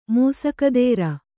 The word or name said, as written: ಮೂಸಕದೇರ